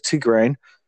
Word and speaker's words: Tigraine: They corrected my pronunciation, says Tigraine.